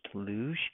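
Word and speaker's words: louche: The kind that I used to brew at home did not, and I'm going to butcher this word, but it's pronounced luge, which is like, it doesn't get cloudy, the homebrew stuff.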